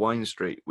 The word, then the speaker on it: Wind Street: It's you got Wine Street, which is spelled Wind Street, but it's what it's pronounced Wine Street, but it's literally just clubs and bars all the way down.